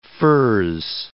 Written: furze

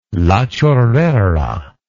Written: La Chorrera

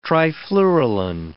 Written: trifluralin

